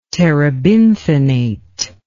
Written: terebinthinate

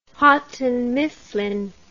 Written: Houghton Mifflin

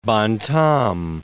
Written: Bontemps

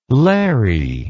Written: lairy

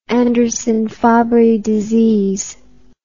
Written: Anderson-Fabry disease

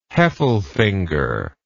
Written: heffelfinger

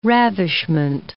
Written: ravishment

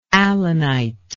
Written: allanitic

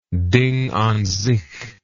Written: Ding an sich

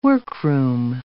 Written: workroom

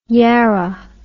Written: yarra

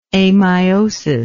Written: ameiosis

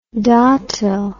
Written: datil

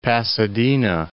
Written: Pasadena

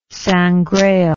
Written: Sangraal

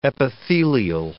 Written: epithelial

